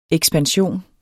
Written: EKSPANSION

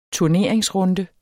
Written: turneringsrunde

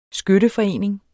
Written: skytteforening

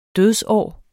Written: dødsår

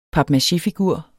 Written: papmachéfigur

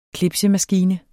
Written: clipsemaskine

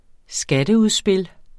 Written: skatteudspil

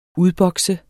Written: UDBOKSER

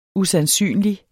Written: usandsynlig

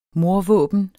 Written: mordvåben

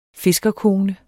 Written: fiskerkone

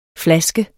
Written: flaske

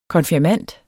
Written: konfirmand

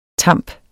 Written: tamp

